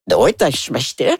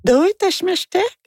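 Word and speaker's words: Doitashmashite (どういたしまして): » Ben, j'ai dit « doit, je m'achetais ». Elle me dit « oh non ». J'ai dit quoi? Non, je prononce pas correctement. Elle dit non, il faut dire Doitashmashite.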